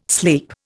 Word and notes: sleep: Indian

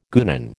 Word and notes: guenon: American English

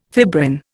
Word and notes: fibrin: Indian

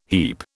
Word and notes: heap: British English

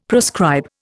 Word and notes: proscribe: Indian

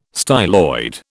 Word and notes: styloid: British English

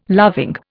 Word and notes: loving: Indian

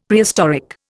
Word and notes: Prehistoric: Indian

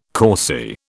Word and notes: causey: British English

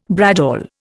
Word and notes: bradawl: Indian

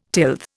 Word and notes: tilth: Indian

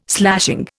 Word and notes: slashing: Indian

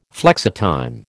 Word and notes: flexitime: American English

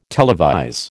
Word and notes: televise: American English

